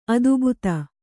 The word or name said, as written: ಅದೆಬದೆ